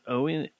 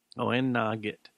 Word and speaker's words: Oweynagat: And the pronounced is Oweynagat. Oweynagat.